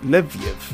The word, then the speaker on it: Leviev: Leviev. I think it is because it's spelled L-E-V-I-E-V. – Leviev.